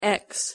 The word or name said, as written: x